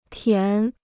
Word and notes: 填: tián

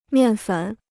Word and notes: 面粉: miàn fěn

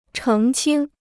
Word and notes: 澄清: chéng qīng

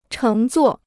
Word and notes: 乘坐: chéng zuò

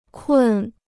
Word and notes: 困: kùn

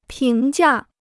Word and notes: 评价: píng jià

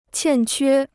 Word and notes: 欠缺: qiàn quē

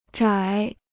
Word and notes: 窄: zhǎi

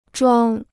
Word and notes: 装: zhuāng

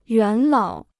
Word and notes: 元老: yuán lǎo

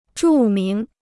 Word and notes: 著名: zhù míng

